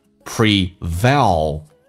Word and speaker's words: prevail: n 跟 l 跟 m 后面加 s 发 z，叫做 emotions prevail over you。 Pre 重音在后面 V 上，咬下唇 A 要夸张一点。